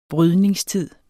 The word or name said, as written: brydningstid